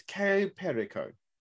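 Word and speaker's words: Cayo Perico: GTA never used to be, but with all the DLCs that are out now, mostly looking at the Kaya Prika heist of Kaya. Probably pronounce that proper wrong because I know it's not quite pronounced that way. – I think it's Kaya Perico.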